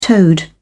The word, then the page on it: toad: British English